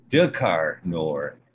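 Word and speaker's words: Drakkar Noir: What is Qatar Noor? If I said it properly, Dakar Noir. It was that fucking scent that you could wear in like in the 90s.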